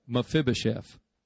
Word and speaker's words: Mephibosheth: Mephibosheth, say it with me. Say it right. Mephibosheth translates destroyer of shame.